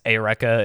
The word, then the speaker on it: Eureka: She is Eureka in Eureka 7. Sorry if you pronounce that Eureka, but that's not how you say it, unfortunately.